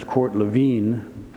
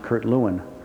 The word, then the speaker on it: Lewin: His name in German is Kurt Lewin, but anglicized, it's often pronounced Kurt Lewin.